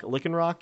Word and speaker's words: Lycanroc: Is that how we're gonna pronounce it? Lycanroc, Lycanroc. – I would pronounce it like that.